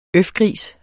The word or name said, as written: øfgris